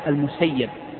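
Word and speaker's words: المسيَّب: طبعا ابن المسيب هو سعيد المشهور، المحدثون دائما ينطقونه بفتح الياء المسيب على وزن اسم الفاعل، وجاء رواية عنه أنه قال إنما أنا ابن المسيب باسم المفعول، سيب الله من سيبني، سيب الله من سيبني. والمحدثون لا يعتمدون هذه الرواية، بل كلهم ينطق اسمه بالفتح وهو الأفصح، فلا يقال ابن المسيب، لأن في صحة هذه الرواية عن سعيد النظر، ثم إن السماع مقدم على ذلك.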